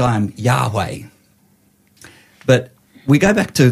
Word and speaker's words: Yahweh: So when you say his name, you don't say Yahweh, you breathe his name. Yahweh. – Yeah, breathe Yahweh. In, breathe out.